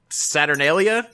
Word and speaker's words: Saturnalia: And she goes, oh, in that case, happy Saturnalia.